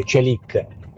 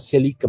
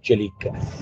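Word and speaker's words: Çelik: Allora, voglio unirmi ai commenti dell'acquisto di Celik, che è così che si pronuncia, non Celik ma Celik.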